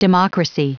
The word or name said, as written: democracy